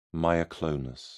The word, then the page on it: myoclonus: British English